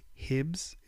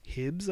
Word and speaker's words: HYBS: It's Hibs. It's literally spelled H-Y-B-S, Hibs, I think, and it's called Dancing With My Phone.